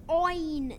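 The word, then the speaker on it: Eoin: Our Aon. Owen Doyle. – Owen, ah. – It's the Irish way to spell it.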